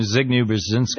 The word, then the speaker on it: Zbigniew Brzezinski: The Grand Chess Game, Zbigniew Brzezinski.